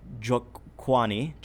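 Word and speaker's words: Njokwani: Chidi Jokwani. The N is silent.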